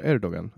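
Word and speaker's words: Erdoğan: Erdogan.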